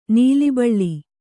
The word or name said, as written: ನೀಲಿಬಳ್ಳಿ